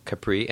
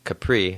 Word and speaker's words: Capri: And so I decided, okay, I want to go to I want to go to Capri, and I, I realized that it's actually pronounced Capri and not Capri.